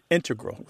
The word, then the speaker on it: integral: Yeah, but both correct, just to clarify here, both correct, but the mathematicians and math-using scientists are more likely to integral with the stress on the first syllable.